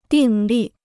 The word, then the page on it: 订立: dìng lì